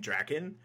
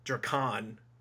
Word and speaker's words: Drakkon: Drakkon, I was mm-hmm. pronouncing it wrong, because wow. I was saying like Drakan. I'm pretty sure I was saying Drakan for like three months.